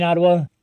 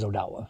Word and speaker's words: Odawa: And to your other question about Odawa and Odawa, in my mind, it's all pronunciation. Yeah, I'm not going to get offended if you call me in Ottawa. I've been called worse, but we hope to call you. – But it's more of an anglicized pronunciation. The original pronunciation is Odawa.